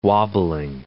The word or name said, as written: wobbling